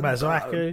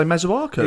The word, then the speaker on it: Masuaku: How do you say Mazuraki? – Uh, Masuaku. Uh, pretty much the same as you, I think. – I, I would say Masuaku.